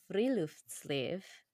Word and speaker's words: Friluftsliv: Your new book focuses on Friluftsliv.